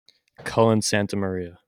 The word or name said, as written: Cullen SantaMaria